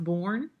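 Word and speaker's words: Boerne: And I think it's pronounced Bourne, Texas.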